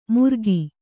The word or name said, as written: ಮುರ್ಗಿ